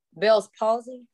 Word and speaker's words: Bell's palsy: 1,411 for Bell's palsy. Did I say that right? – Yes.